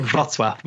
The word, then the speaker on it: Wrocław: It's called Wrocław, which is Wroclaw, but L okay. with a line through it.